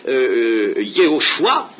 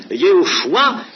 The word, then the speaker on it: Yehoshua: Remarquez une chose extraordinaire, c'est que Josué, on peut le prononcer son mot, son nom, Yehoshua, n'est-ce pas? Yehoshua Amashia, ce qui veut dire maintenant Jésus-Christ.